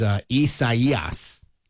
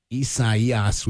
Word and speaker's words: Isaias: Because I don't know who picks names, but the pronunciation is Isaias. Isaias, with the emphasis on that that third syllable.